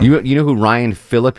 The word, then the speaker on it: Phillippe: Kind of the metrosexual kind of, Ryan Phillippe.